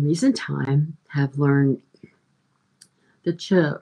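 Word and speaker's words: Chöd: It's C H O. It's actually spelled C H O and two little dots over the O, or it's spelled C H O D. The two dots make it chud, um, a light D at the end of it.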